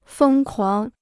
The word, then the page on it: 疯狂: fēng kuáng